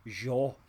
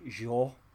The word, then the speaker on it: Zhao: It's actually pronounced Zhao. So it's Chloe Zhao.